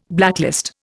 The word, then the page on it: blacklist: Indian